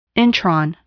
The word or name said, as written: intron